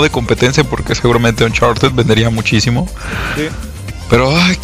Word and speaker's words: Uncharted: ¿Uncharted o Uncharted? – Uncharted. – Uncharted, ok, Uncharted 4.